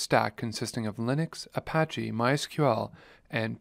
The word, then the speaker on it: MySQL: MySQL is a popular open source relational database management system, and yes, it is in fact pronounced MySQL.